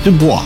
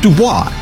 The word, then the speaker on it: Dubois: He was Toussaint Dubois, pronounced Dubois in his time, and Du Bois by the Hoosiers that followed.